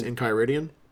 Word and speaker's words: Enchiridion: This is called Izerians Enchiridians. Hope I said that right. – I think it's Enchiridion. – Enchiridion of the West Marches.